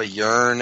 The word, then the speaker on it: Yern: Yearn, as in like yearning to be free? – Y-E-R-N. – E-R-N, okay.